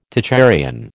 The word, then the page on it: Tocharian: American English